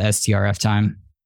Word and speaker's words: strftime: and I don't know how to pronounce that or if you just spell it out, strf time, strf time? – I call it strf time.